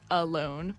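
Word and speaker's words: VLONE: Space Ghost was like, it should be pronounced alone because the V is like an A.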